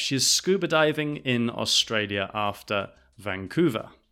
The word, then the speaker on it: Australia: Also, pronunciation Australia, not Australia or Australia, it's Australia. Australia.